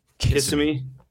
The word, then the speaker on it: Kissimmee: Kissimmee.